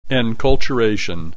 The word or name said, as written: enculturation